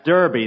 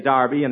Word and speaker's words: Derby: Bill, that's Derby. The English say Derby, and the Americans say Derby.